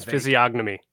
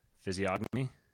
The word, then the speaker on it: physiognomy: How do you pronounce physiognomy? How do you pronounce it? – Physiognomy. – Yeah, you pronounce it that way. – Yeah. – Okay, Josiah Spergen out because the G is apparently silent.